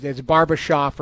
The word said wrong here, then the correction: Barabanov: or whatever his name is. – Barabanov.